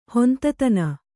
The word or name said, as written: ಹೊಂತತನ